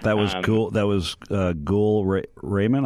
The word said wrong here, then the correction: Rahman: I'm not sure if I'm pronouncing that Rahman. – Right. But Rahman, got it.